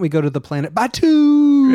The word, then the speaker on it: Batuu: So when you do go to the planet Batu, I don't know, B-A-T-U-U. How would you say that, spaceman? – Batu. – Oh, that's how you'd say it.